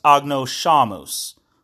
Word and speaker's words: agnoscamus: If you see a letter S followed by a letter C, in the traditional church pronunciation, that is pronounced as sh. So hence, agnoscamus.